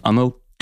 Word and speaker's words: Anil: Ähm, es ist ja eigentlich nicht Ani, sondern Anil.